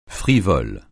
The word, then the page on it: frivole: francais